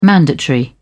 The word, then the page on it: mandatory: British English